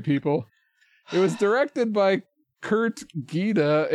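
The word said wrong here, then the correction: Geda: Well, on that Kurt Jetta. – Oh, it is Kurt Jeddah.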